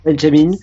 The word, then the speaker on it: Benjamin: Et les applaudissements que vous avez entendus avant la prestation suédoise sont un contraste terrible avec le résultat de Benjamin, je ne sais pas si on prononce Benjamin en... – Benjamin, en suédois.